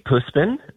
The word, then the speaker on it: Puspin: Puspin? Puspin? How do you say that? – It's a Sweet Puspin. It means a Filipino street cat.